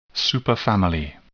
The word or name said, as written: superfamily